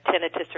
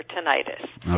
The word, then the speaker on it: tinnitus: Well, first of all, the pronunciation of tinnitus and tinnitus are equally weighted. So depending on who you are and where you're from, you'll call it either tinnitus or tinnitus.